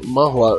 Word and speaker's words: manhua: Manhua.